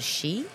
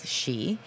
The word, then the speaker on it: Ski: It's called She. It's spelled like SKI. – Yep. – I think, but the pronunciation is She.